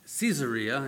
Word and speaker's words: Caesarea: Caesarea is probably the right way to pronounce that.